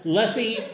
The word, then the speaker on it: Lethe: But he sent me in an email, rhymes with Jiffy. How do you get Lethe to rhyme with Jiffy? – That's L-E-T-H-E.